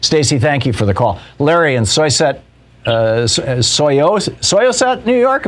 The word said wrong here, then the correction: Syosset: Am I saying that right, Larry? – Close. Syosset.